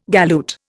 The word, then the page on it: galoot: Indian